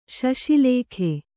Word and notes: ಶಶಿಲೇಖೆ: Kannada

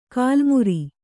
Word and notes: ಗಲ್ಲುಮರ: Kannada

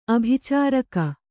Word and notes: ಆಭಿಚಾರಕ: ābhicāraka

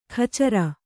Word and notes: ಖಚರ: Kannada

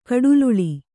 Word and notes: ಕಡುಲಲ್ಲೆ: Kannada

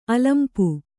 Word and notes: ಅಲಂಪು: alampu

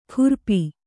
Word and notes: ಖುರ್ಪಿ: khurpi

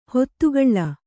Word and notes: ಹಿತಗೊಳ್: hitagoḷ

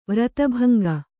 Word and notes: ವ್ರತಭಂಗ: Kannada

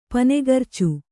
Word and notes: ಪನೆಗರ್ಚು: Kannada

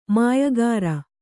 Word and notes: ಮಾಯಕಾಱ: māyakāṛa

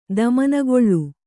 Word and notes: ದುಮ್ಮಾನಗೊಳು: Kannada

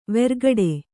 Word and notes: ವೆರ್ಗಡೆ: vergaḍe

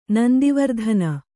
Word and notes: ನಂದಿವರ್ಧನ: nandi vardhana

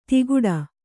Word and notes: ತಿಗುಡ: tiguḍa; kannada

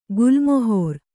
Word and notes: ಗುಲ್ಮಹರ್: Kannada

